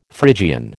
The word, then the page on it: Phrygian: American English